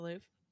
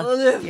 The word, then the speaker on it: Louvre: That's how you say it, right? The Louvre. – The Louvre. Louvre. Yeah, it's Louvre. Get your phlegm out.